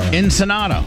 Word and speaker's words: Ensenada: Some Ensenada. – Ensenada. – How do you say it? – Ensenada. – Ensenada.